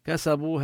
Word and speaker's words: كسبوا: لعجل لهم العذاب، كسبوا هذه الألف التي وضعت بعد الواو لا أثر لها في النطق.